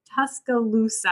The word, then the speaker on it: Tuscaloosa: And you do currently work at the Tuscaloosa. Am I saying that right? – You are. That's correct.